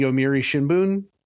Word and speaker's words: Yomiuri Shimbun: Okay, so the book is about the first American writer for the Yomiuri Shimbun. – Yeah. – Am I saying that right? – Yeah, pretty much.